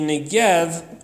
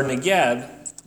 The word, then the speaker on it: Negev: But if you've seen a map of the Holy Land, the Negev, or Negev, depending on how you want to pronounce it, is the wilderness or desert which covers most of the southern part of Israel.